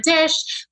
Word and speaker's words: Disch: So Instagram is at Tara Disch.